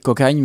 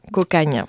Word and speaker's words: Cocanha: On va le répéter, Cocagne, même si ça s'écrit C-O-C-A-N-H-A. – C'est ça, Cocagne.